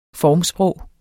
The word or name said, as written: formsprog